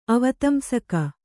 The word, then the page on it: ಅವತಂಸಕ: Kannada